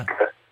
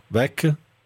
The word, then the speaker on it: Beck: Beck. – Bravo, Beck.